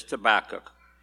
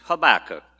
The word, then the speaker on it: Habakkuk: When I grew up, I mispronounced it as Habakkuk, but it's Habakkuk, not tobacco.